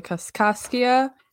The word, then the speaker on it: Kaskaskia: I am recording in North Canton, Ohio, on land belonging to the Kaskaskia Nation.